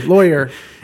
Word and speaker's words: lawyer: In most of the United States, lawyer is pronounced to rhyme with boy. – Like boyer. Boyer, lawyer. – In the South, excluding Texas and Florida, not really South, I've been told, it's pronounced to rhyme with Saul.